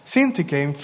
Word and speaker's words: Syntyche: Likewise, we see women who were co-workers with Paul in his ministry, such as Euodia and Syntyche. That's the right pronunciation? Yes. Syntyche in Philippians 4:3, where Paul says that these women had worked side by side with him in the gospel.